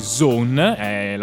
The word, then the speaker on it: Sohn: Non scegliamolo più, se vogliamo parlarvi di Zone, la pronuncia è in tedesco perché appunto la parola è scritta S-O-H-N, che vuol dire figlio in tedesco, e l'artista in realtà si chiama Christopher Taylor, abbiamo detto, musicista sì.